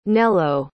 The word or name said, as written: nello